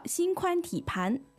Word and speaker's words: 心宽体胖: 那生活当中绝大部分的人都说心宽体胖。 – 但是它正确的读音呢是盘。 – 就是字典当中的读音啊。 – 嗯，那我们就叫心宽体盘，那其实就是胖的意思了。